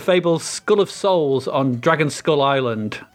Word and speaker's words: Dragonskulle: Dragon Skull, spelt with an E.